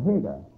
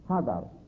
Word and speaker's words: Hagar: This is Egyptian Arabic pronunciation of Hagar, Hagar.